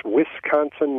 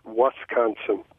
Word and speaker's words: Wisconsin: It's Wisconsin, not Wisconsin.